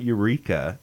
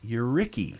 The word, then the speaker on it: Eureka: No, it's not Eureka. It's Euricky. – Well, like, Y-O-U-R-R-I-C-K-Y? – They can, I mean, they pronounce Lebanon.